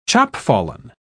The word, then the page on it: chopfallen: British English